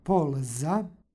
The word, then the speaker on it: polza: U korenu reči stoji tvrdo l, tako da izgovaramo polza.